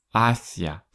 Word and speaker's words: hacia: Hacia. Пишется он через букву H, которую мы не читаем. Hacia. Ударение на первый слог.